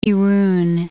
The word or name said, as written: Irun